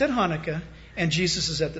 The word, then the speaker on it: Hanukkah: That is Cheslev 25th, or what we call Hanukkah, or Hanukkah. You got to spit when you say it if you say it right.